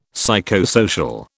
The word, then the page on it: psychosocial: British English